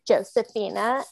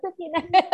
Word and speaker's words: Josefina: because we were all calling her Josefina, and she was like, no, it's Josefina.